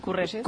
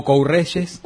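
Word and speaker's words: Courrèges: Bueno, yo lo que digo es que si por lo menos lo leen bien y dicen Courreyes, bueno, está. Convengamos que pronunciarlo en francés es como... Es difícil, ¿no?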